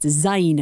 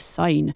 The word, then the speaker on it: Syne: Auld Lang Syne, sign pronounced zine instead of sign.